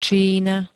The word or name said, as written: Čína